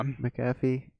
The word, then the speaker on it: McAfee: I think John McAfee, that's how you say it? – Yeah. – McAfee.